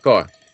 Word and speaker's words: Mackay: Is it pronounced McKay? – Mackay. The same as Jervis Bay.